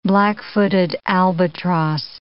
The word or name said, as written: black-footed albatross